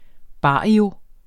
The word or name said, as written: barrio